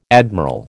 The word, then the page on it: admiral: American English